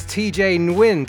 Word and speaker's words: Nguyen: Welcome to Investories, TJ Nguyen.